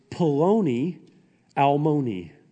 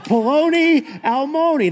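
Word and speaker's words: Poloni Almoni (פלוני אלמוני): Here is the Hebrew phrase in verse 1 it's Poloni Almoni, rhymes with baloney or John Oney. All right. Poloni Almoni.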